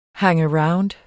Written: hangaround

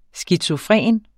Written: skizofren

